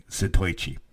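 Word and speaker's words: Zatoichi: That's how he said it. I was saying it wrong at the beginning. Satoichi.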